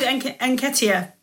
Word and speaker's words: Nketiah: I heard Nketiah, and I think you do say the N as well.